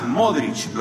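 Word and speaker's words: Modrić: Después tenés los croatas y otra vez el mundial, que terminan en ic, aunque ellos pronuncian ich, Lucas Modric, los futboleros, Rakitic, hijo de un hombre que no tiene que haber sido muy gordo si se llamaba Rakitic, los armenios.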